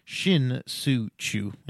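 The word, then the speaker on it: Shin-Soo Choo: Sin-Soo Chu, Shin-Soo Chu, and say it right because he won me a title,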